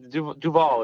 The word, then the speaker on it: Duvall: Like Duvall is the right way to say it. It's basically the band is named after Robert Duvall.